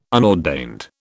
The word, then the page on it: unordained: British English